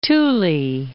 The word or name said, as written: Thule